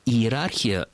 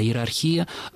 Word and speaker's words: иерархия: Иерархия сейчас в современном русском языке произносится только с этим ударением, а иерархия словари помечают как устаревшую форму.